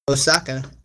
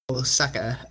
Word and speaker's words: Otosaka: So I guess we'll start with the main character, you, Osaka, which it's weird because his last name is spelled Otosaka. I'm pretty sure the T is silent. – And so it's a second name? – Yeah, I guess. Osaka.